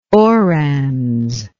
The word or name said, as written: orans